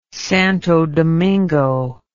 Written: Santo Domingo

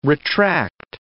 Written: retract

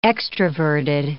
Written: extraverted

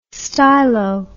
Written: stylo

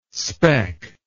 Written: spec

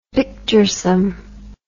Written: picturesome

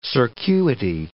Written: circuity

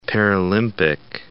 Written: Paralympic

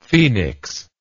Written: phenix

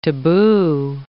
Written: tabu

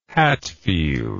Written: hatfield

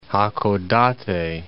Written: Hakodate

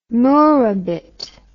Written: murabit

